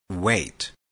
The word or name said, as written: wait